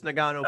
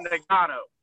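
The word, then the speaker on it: Ngannou: Stipe Miocek versus Francis Nagano for heavyweight championship. – You butchered both of those names. – I don't know how this. – It is Stipe versus Nagano.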